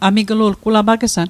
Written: amiglu'lg

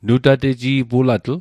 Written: nutateji'pulatl

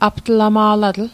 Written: aptlama'latl

